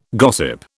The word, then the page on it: gossip: British English